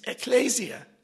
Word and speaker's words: ekklesia: is ekklesia. – Heißt Ekklesias. – Did I say it the right way? – Habe ich das richtig gesagt? – Ekklesia. Ekklesia. Okay.